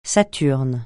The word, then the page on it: saturne: francais